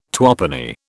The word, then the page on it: twopenny: British English